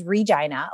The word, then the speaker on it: Regina: Yes, I said it right. It is Regina.